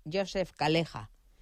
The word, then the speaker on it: Joseph Calleja: Se llama, vamos a decir cómo se le dice en maltés, que es Joseph Calleja. Se escribe Joseph Calleja. Yo siempre la había llamado Calleja hasta que le oía a él decir Calleja, ¿no?